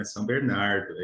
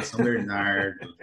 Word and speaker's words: São Bernardo: Tava na hora de você aprender a falar o nome da minha cidade, né? São Bernardo, é isso mesmo. – Eu falei certo, Zé, agora. – Falou certinho agora, São Bernardo.